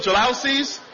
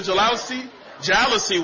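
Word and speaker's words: jalousie: My wife and I, when we first got married, we had a 1,200 square foot apartment with those old jalousies. That's how you pronounce it, the jalousy windows.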